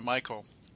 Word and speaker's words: Michial: Um, the truth is, M I C H I A L is the spelling for Michael is apparently a Celtic spelling, or so I've been told.